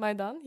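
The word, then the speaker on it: Maidan (Майдан): Maidan, yeah.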